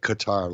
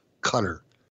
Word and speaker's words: Qatar: Not Qatar, like guitar, cutter. I've been reprimanded on Twitter for pronouncing it wrong. It's not Qatar like guitar. It's cutter.